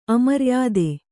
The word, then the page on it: ಅಮರ್ಯಾದೆ: amaryāde